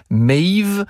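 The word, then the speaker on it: Medb: Alors ça s'écrit d'une drôle de façon, ça s'écrit Medbe, mais ça se prononce Maeve.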